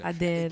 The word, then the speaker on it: Adele: C'è Adele, o Adil, come si pronuncia? – Adele.